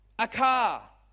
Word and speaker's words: achar: Right there, the second ingredient, because he had an akar spirit and because he followed me. The word follow me is very similar to A-C-H-E-R. It's spelled A-C-H-A-R, akar.